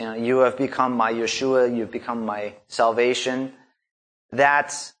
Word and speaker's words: Yeshua: it's spelled in your Bible Jeshua, but it's Yeshua, and has that spelling of the Yod, the Shin, the Vav, and the Ayin.